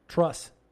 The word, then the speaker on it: Truss: Is that how you pronounce it? – Truss.